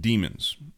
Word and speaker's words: daemons: Demons spelled D-A-E-M-O-N-S, pronounced demons.